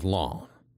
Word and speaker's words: Lens: Lens. That is spelled L-E-N-S, like the English word lens.